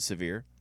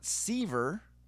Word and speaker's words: Sevier: What makes it the worst is when tourists will call it Sevier County.